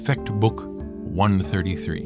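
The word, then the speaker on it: Fechtbuch I.33: And even though it is written as capital I dot 33, that's how it's pronounced, Fecht Book 133, in case you want to look it up.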